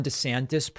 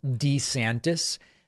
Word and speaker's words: DeSantis: Now, one other just like kind of funny thing, you might have noticed that in that ad, Ron DeSantis pronounced his name DeSantis. This is like a kind of funny thing. Ron DeSantis in older videos used to pronounce his last name DeSantis. And then more recently, while he's been governor, it's been DeSantis. And then in that video, he went back to DeSantis, and it's all kind of weird.